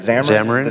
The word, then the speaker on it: Xamarin: There was a demo, the Xamarin, am I pronouncing it right? – Xamarin.